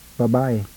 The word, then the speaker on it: babae: First, a few words where the stress falls on the penultimate syllable. As I pronounce an English word, would you please sound the Tagalog word? Woman. – Babae.